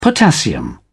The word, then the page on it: potassium: British English